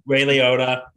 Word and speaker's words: Ray Liotta: Roy Liotta. Is that how you say his name? – Ray Liotta.